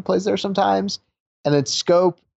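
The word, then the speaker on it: Schoop: And then the Brewers, with their trade deadline moves, adding Mike Moustakas and Jonathan, how do you say his last name? Scope? – Yep, Scope.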